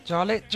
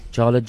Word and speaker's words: Jarlette: Jarlett Joy. – Jarlett Joy. – Yes. – But the Jarlett is spelled? – J-A-R-L-E-T-T-E, and then...